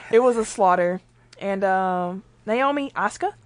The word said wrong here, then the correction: Osaka: I always say her name like the wrestler. – Osaka. – Osaka. – It's like the city in Japan.